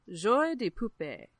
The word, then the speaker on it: Joie de Poupée: But if we were speaking French, it's French words. It's Joie de Poupée.